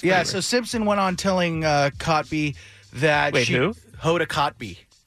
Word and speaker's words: Kotb: She told Cotby. – I don't even think that's how you say it. – Yeah, Hoda Cotby.